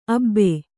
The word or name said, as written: ಅಬೆ